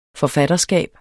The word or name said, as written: forfatterskab